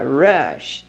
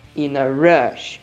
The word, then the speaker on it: rush: Hurry tem um som de R, porque ele começa com H. In a hurry. Mas o outro ele começa com R, e aí ele não tem um som de R com h, h ele tem um R mordido. Rush. In a rush.